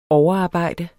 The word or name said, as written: overarbejde